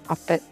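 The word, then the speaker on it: OFB1T: It's spelled a little differently, or OFB, the number one T, Offbeat. And I searched everywhere to see where this person is from, and I can't find anything.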